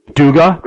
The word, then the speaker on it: Dugas: By the way, am I pronouncing Graham's last name correctly, Dugas? – I don't pronounce the S, just Duga? – Duga. – Duga. – He said it's like the painter Degas, but without the E.